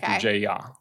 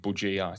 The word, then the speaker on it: Bujaya: The J is like a Y. – Okay. – Yeah, Bujaya. Yeah. Sarah's like, I don't know how to pronounce his last name. – Bujaya. It's actually Arabic. – Oh, is it? – It's Maltese background, but it's an Arabic name.